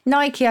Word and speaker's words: Nike: Is it pronounced Nike or Nike? – Nike, I think, named after the goddess of victory.